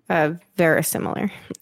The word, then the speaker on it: verisimilar: So now the question was, what really makes something verisimilar? Did I say that right? You just looked at me like... – No, that was good.